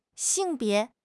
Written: xing4 bie2

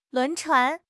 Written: 轮船

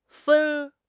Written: fen1

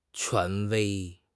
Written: 权威

